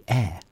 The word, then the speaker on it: Eyre: How do you say E-Y-R-E? Air. I've always said Ella Air.